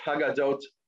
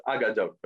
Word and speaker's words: Haggadot: Other narrative materials are Haggadot, which then becomes pronounced Agadot, because some people don't like pronouncing Hayes. It's true in Israel today also, right? You go to Yerushalayim, people don't pronounce their Hayes. So Haggadot becomes Agadot.